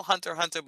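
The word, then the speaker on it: Hunter × Hunter: It's called Hunter Hunter, but it's spelled Hunter X Hunter and she is the most beautiful black character I've ever seen drawn.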